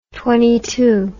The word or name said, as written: twentytwenty